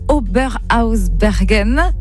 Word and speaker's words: Oberhausbergen: Vous avez donc tourné en Alsace, notamment à Oberhausbergen.